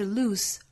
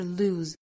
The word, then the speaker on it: lose: It fools smart people into thinking, 最后 they can't lose, they can't 尾部的 t 略掉， lose 尾部的 s 一读浊辅音，不是 lose 而是 lose。